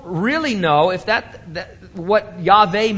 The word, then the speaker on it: Yahweh: And so Now, if you really want to split hairs, since we're getting deep, deep into this, you need to know that there's no W sound in Hebrew. So the most correct transliteration would use a V instead of that W, and the pronunciation would be Yahweh.